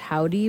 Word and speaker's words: Hovvdy: Before that, we heard Blast by what I assume to be pronounced Howdy, but is spelled H-O-V-V-D-Y.